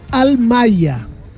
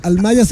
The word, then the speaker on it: Almaia: Almaia. Almaia se escribe tal cual. A-L-M-A-I-A. A-I-A. Almaia. – Que debe ser como alma y a.